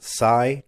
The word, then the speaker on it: Tsai: Brooklyn Nets owner Joe, is it pronounced Sai, T-S-A-I, expressed public disappointment in Irving in a tweet on Friday.